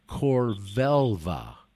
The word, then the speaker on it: Corvelva: Vaccine advocacy, vaccine advocacy group Corvelva, and it's spelled C O R, and then like Velveeta, V E L V A.